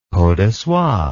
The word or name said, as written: peau de soie